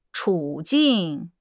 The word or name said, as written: 处境